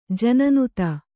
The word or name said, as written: ಜನನುತ